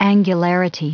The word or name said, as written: angularity